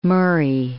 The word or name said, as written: Murray